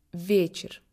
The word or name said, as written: soir